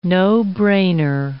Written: no-brainer

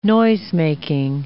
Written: noisemaking

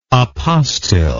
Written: apostil

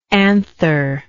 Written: antherless

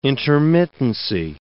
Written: intermittency

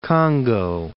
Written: Congo

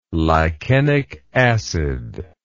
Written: lichenic acid

